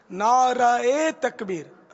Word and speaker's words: نعرہ تکبیر: اول تو نعرہ تکبیر بھی غلط ہے نعرہ تکبیر را پر زبر ہے